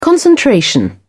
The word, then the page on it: concentration: British English